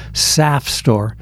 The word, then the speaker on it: SAFSTOR: It really should be pronounced SAF store. And SAF rhymes with sap.